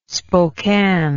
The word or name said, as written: spokane